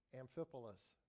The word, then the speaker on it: Amphipolis: through Amphipolis? Amphipolis. Is that right? I'll spell it for you. A-M-P-H-I-P-O-L-I-S.